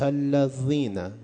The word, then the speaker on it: الذین: توی نماز شما می خونید صراط الذین، دال زال رو چجوری باید تلفظ کرد؟ صراط الذین، سر زبونتون باید بیاد بین دندونای پایین و بالا، دندونای پیش، بین دندونای پایین و بالا یک کمی سر زبون میاد بیرون.